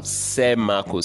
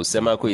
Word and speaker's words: Semako: Sam Semako. Semako is spelled S-E-M-A-K-O.